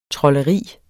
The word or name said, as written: trolderi